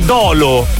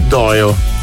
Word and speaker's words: Dolo: E ricordatevi che Dolo è sbagliata come pronuncia. Quando avete scritto il paese Dolo, non si legge Dolo. – Però dovrebbero fare come in... – Si scrive Dolo, ma si legge Doio.